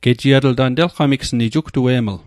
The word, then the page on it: gejiatl: Mi'gmaq